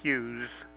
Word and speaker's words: Hughes: I may or may not be the patient, but my name is Jeffrey Hughes, and I get automatic deliveries every couple of months.